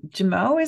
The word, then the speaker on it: Jimoh: Yes, l- love Sam, love the character Sam, and um, Jimoh is how you say his last name.